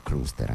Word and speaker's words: Cluster: Tra i pochissimi gruppi a suonare, di fatto lo sentirete, un suono molto moderno, sicuramente influenzato dai Can, dai Noi, dai Cluster, dai Cluster, anzi pronuncia alla teutonica, e ascoltate questo Just a Little Bit on the dei Sensations Fix, tratto dal loro secondo lavoro Fragments of Lights.